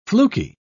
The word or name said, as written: fluky